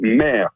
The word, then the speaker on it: Meyre: C'est le château Mer. Ça s'écrit M-E-Y-R-E.